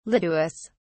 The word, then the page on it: lituus: British English